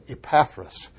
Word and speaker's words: Epaphras: And then let's talk about Epaphras. Now I like to use this pronouncing Epaphras, but Epaphras is probably the more correct way to pronounce that.